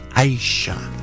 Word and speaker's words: Aja: And here's a track from an album that's not called Aja or Aja. It's pronounced Aisha.